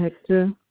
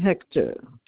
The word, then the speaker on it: Hector: It's Naldine Hector. – What is it? – Naldine Hector. – Spell that last name for me, please, if you don't mind. – H-E-C-T-O-R.